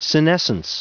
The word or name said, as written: senescence